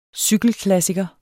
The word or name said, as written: cykelklassiker